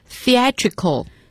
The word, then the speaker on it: theatrical: 下面一个词，剧场的，戏剧的， theatrical 那么要注意这个单词的发音，它和 theater 是有差别的， theater 是剧院，这个地方是 theatrical 发音要注意，把舌头放在牙齿之间才能发得更准确。